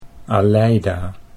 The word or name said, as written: Aleida